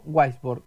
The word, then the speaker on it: Waisbord: Sus autores, Martín Becerra y Silvio Weisborg. ¿Lo pronuncié bien el apellido de Silvio? Sí, sí. Bien, perfecto.